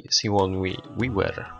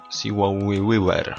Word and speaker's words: Sigourney Weaver: Całą tą serię poza oczywiście Obcym łączy jeszcze jedna postać, pani Sigourney Weaver. Sigourney Weaver. A może po prostu Sigourney Weaver.